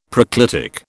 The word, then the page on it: proclitic: British English